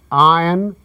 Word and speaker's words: Ayn: Leonard, she is someone who, even the people who have read her novels, a lot of people pronounce her name Ayn Rand, which is what we find all the time. – Ayn rhymes with mine. That's what she used to say.